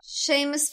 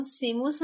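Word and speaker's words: Seamus: حالا ما با شیمس فینیگان که خب تلفظ اصلی این اسم هست کاری نداریم، به همون سیموس هم رضایت داده بودیم، ولی آیا سیمون فینیگان دوستان؟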